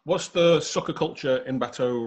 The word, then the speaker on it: Bateaux: How are we pronouncing it? Bateaux? – Yeah, Bateaux. So it's, you know, two syllables.